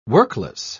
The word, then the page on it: workless: British English